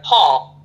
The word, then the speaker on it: Hall: It's spelled D as in David, O, N as in Nancy, Y, A, L-E, now my last name is much easier, Hall, H-A-L-L.